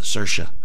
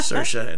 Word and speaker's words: Saoirse: And along with Saoirse. I said it right. – Yeah. – Saoirse and Adam Driver.